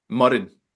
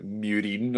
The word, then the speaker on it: Muireann: How are you pronouncing her first name? – I believe it's Muireann. – Muireann. Okay. I was going to butcher it and say like Muireann or something like that.